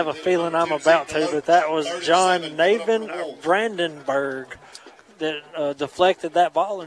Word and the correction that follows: Jonathan: I think you pronounce that Jonathan Brandenburg.